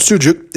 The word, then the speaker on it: sucuk: It's called sucuk. It's spelled S-U-C. You say, well, how do you get sucuk out of that? Well, in Turkish, C, this is normal C, isn't like in English. It's actually, it makes the J sound J.